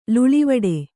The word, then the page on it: ಲೀಲೆವಡೆ: līlevaḍe; Kannada